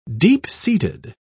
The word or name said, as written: deep-seated